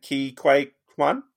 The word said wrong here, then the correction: Ke Huy Quan: Ke Huy Quan.